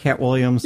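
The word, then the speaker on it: Katt Williams: Am I getting the name right, Cat Williams? – Yeah.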